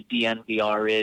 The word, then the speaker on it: DNVR: So that's what the DNVR is, and it is pronounced DNVR, but it's Denver without the E's.